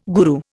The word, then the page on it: guru: Indian